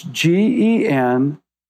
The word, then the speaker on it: Genshai: Genchai, it's pronounced almost like gunshy, but it's Genshai.